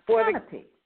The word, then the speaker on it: Kanopi: Because I noticed it's spelled K K-A-N-O-P-I, and pronounced canopy.